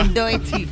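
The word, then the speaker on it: Doechii: Doechi.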